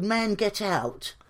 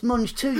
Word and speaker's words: mangetout: And she went, it's spelled Man Get Out. Well, spell it, MANGETOUT. I went, it's Mange 2, you stupid cat.